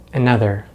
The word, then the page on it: another: American English